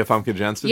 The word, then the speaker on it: Famke Janssen: For the version they had on screen, I hated, how do you say her name, Famke? – Yeah, Famke Janssen.